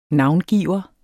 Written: navngiver

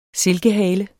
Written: silkehale

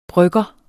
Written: brygger

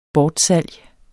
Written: bortsalg

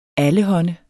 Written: allehånde

